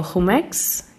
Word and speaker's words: Jumex: including Museo Jumex. Do I pronounce that correctly? – Yes, perfect.